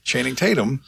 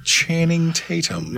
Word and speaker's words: Channing Tatum: He made Tatum Chanum his bitch. – Channing Tatum. Tatum Chanum is not a guy. – You couldn't say it right either. – Channing Tatum.